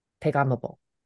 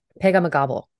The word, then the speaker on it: Pegahmagabow: That's good, because I actually missed a syllable. Pegahmagabow. That's what it's supposed to be.